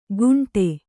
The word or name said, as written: ಕುಂಟೆ